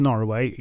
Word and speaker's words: Norroway: While I'm going to pronounce Norroway exactly like that, it is absolutely an alternate spelling of Norway, but one which exists pretty much only in this story.